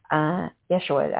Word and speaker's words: Yeshua: I think Jeshua is his Hebrew name. Yeshua, I think is how they say it. I always say the J, but hey, it's okay.